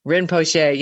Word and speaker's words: Rinpoche: And it turns out that like over 1200 years ago, there was a great Tibetan master guru, Rinpoche. I'm sure I did not pronounce that right. – Rinpoche. – Yes.